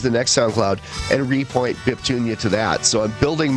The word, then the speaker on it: Biptunia: I went out and bought the band name, which is Biptunia, B-I-P-T-U-N-I-A. It's pronounced Biptunia or Biptunia. Both are acceptable.